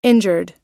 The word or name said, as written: injured